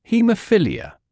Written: haemophilia

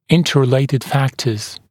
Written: interrelated factors

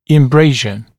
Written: embrasure

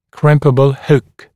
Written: crimpable hook

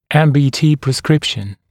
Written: MBT prescription

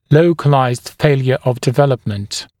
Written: localized failure of development

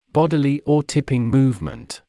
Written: bodily or tipping movement

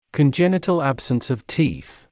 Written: congenital absence of teeth

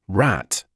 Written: rat

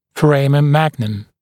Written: foramen magnum

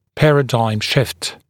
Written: paradigm shift

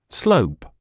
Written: slope

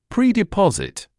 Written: predeposit

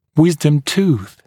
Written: wisdom tooth

